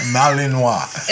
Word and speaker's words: Malinois: How do you say that? Malinois. Is that like a Pinot? – Yeah. Malinois.